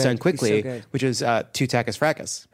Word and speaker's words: Frakes: And I assumed for no reason that it was Jonathan Frakes. And Sam correctly said, no, no, no, you're wrong. It's obviously Frakes.